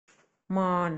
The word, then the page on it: มอญ: [mɔ̄ːn]; Thai